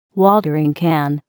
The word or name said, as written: watering can